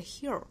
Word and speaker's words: heal: 好，下面我们来看美音的发音。Heal the world, heel，注意舌侧音尾部哈，舌尖是在上齿龈的位置，不是 heel，而是 heel。也注意这个词跟 h i l l 的发音的区别哈，一个是紧音的 eel heel，一个是山松音的 hill hill。